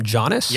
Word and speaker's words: Giannis: Did you hear Giannis's post-game? – Call him Giannis? – Giannis.